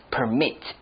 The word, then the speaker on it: permit: Here it's a verb, permit. Okay, the emphasis is on the second syllable, permit.So you could permit someone to do something because to permit something means to allow something.